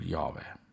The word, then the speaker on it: Jahve: Hääldatakse küll Jahve.